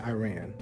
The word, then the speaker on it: Iran: Today, we're going to look at the country of Iran, or Iran, as it should be pronounced, Iran, but many of us say Iran, either one.